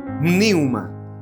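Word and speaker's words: niuma (πνεῦμα): Se dice como con una P, niuma.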